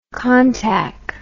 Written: contac

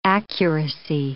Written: accuracy

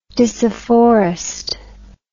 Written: disafforest

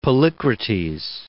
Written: Polycrates

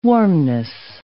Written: warmness

